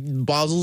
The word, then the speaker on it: Basel: Art Basel, like Basel, Switzerland.